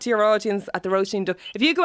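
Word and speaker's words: Róisín Dubh: So, Roshan Derb. – Oh, no. – Is that anywhere near? – Tom. No. Roisin Dove. Roisin Dove. You know, like versus the Dove. So think of Dove like as in the bird, or the, you know, the skincare, the soap, and all that. But it's the BHs in Irish are pronounced like a V.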